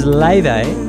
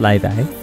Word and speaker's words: Laufey: This is Layday. It's written L-A-U-F-E-Y, but it's pronounced Layday.